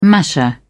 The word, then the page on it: masher: British English